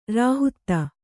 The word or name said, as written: ರೋಹಿತ